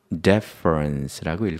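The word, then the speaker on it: deference: 읽을 때 강세가 맨 앞에 있어요. 그래서 deference라고 읽고 경의라고 합니다.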